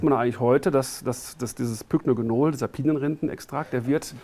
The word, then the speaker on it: Pycnogenol: Pycnogenol. – Pycnogenol, genau, ja.